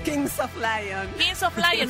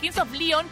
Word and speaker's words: Kings of Leon: Kings of Lion, no le iba a sacar. Kings of Leon, porque Leon...